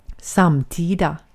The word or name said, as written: samtida